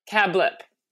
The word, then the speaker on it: CABLP: C-A-B-L-P. Kablip. – Cab-L-P. – Cab... More like C-A-blip. Kablip. – And did you look up the pronunciation? – I did. It says it right here. It's pronounced, in this article I'm reading, it says it's pronounced Kablip, but it's spelled, it's spelled Kab-L-P, but it's pronounced Kablip. I'm going to say it again.